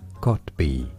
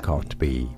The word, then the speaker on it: Kotb: Let me look it up. – You know I don't know. – I need to look it up because I honestly do not know how to say this shit. All right, let's see. Hoda, how to pronounce? – Booze. How do you say it? Hoda Kotb. – Oh, it's Hoda Kotb. – Okay. – Pretty straightforward once you know.